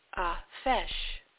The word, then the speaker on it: Feis: Now, Samhain is also based on the festival called Fesh, and that's spelled F-E-I-S. So, called Fesh in Ireland,